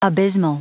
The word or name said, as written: abysmal